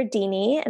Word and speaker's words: Deanie: So my Instagram is at Dr. Deanie, and my name is spelled D-E-A-N-I-E.